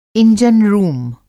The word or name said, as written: इंजन रूम